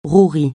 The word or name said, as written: rouerie